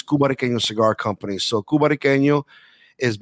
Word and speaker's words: Cubariqueño: but the actual name of the company is Cubariqueño.